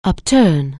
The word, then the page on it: upturn: anglais